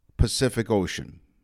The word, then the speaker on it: Pacific Ocean: The letter C is pronounced three different ways in Pacific Ocean. – Oh, one is a ick, and one is a shun, and one is an S.